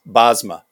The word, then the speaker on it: Bosma: Yeah, Bosma. Pronounce the S like it's a Z. Bosma.